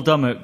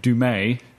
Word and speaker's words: Dummett: Paul Dummett became Dumay. – Dumay. – For like, some reason. – Like, yeah. Well, that was the co-commentator went for Dumay.